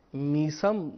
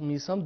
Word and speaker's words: میثم: انہوں نے میثم کا صحیح تلفظ بیان کیا کہ میثم نہیں بلکہ میثم ہے، جس طریقے سے ہیثم ہوتا ہے اسی طریقے سے فرمایا کہ میثم ہے یعنی میثم۔